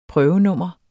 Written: prøvenummer

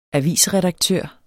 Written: avisredaktør

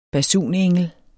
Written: basunengel

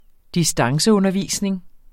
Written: distanceundervisning

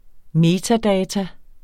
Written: metadata

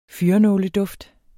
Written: fyrrenåleduft

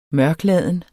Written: mørkladen

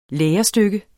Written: lærestykke

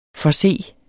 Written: forse

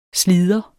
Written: SLIDERS

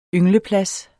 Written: yngleplads

